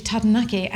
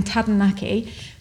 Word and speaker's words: Taranaki: They said, if you have a problem rolling your R's, as in Tadnaki, replace the R in Tadnaki for a D.